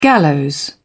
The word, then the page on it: gallows: British English